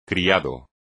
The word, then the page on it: criado: espagnol